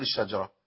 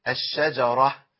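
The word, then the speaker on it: الشجرة: الشجره فتحات ورا بعض، حركات زي بعضها بالضبط ورا بعضها، اعمل فيها ايه؟ لازم انطق ايه الفتحات دي كلها، اقول ايه؟ الشجره ايوه كده، ما اقولش الشجره يبقى انا كده سكنت الجيم.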